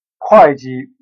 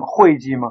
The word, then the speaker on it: 会计: 会计不能念会计吗？ – 不能。其实汉字都是一个字，不知道为什么把会计念成会计，比如工会、商会、开会，都是会的音。